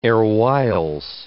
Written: erewhiles

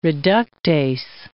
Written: reductase

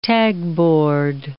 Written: tagboard

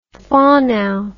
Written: whanau

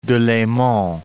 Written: Delemont

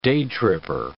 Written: day-tripper

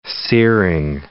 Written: searing

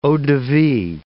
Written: eau-de-vie

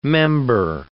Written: member

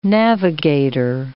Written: navigator

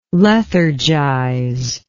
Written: lethargize

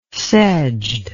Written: sedged

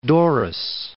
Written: Doris

